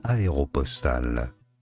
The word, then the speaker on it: Aéropostale: We are looking at how to say, how to pronounce, in French, aéropostale. – Aéropostale. – It's a French word, okay? So, you're wrong. – Yeah, we gotta Americanize it and go aéropostale. – It's aéropostale. Is exactly how you, it is. – How you pronounce it in French.